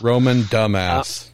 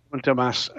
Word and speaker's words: Romain Dumas: So it was indeed Romain Dumas. – Romain Dumas. Yes. – Yeah, Romain Dumas.